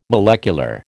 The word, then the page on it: molecular: American English